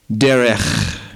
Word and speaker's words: derech: Now, another similar idea to walking is this Jewish concept of following the Hebrew word, which I like to pronounce, derech.